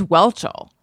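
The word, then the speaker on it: Whelchel: Because I pronounced it similarly Witchell? One time. – Oh. – And people got roasted by her? – No. I wish. I wish. No, but I think people were roasted me because I think it's just Welchel.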